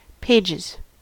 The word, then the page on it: pages: American English